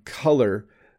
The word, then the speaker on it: color: And color just has a little bit of a slight difference to the beginning of the pronunciation, cull. It's cull, more like the verb to cull, which means, you know, sometimes we have 100 plants and 3 aren't doing well, so we'll cull them.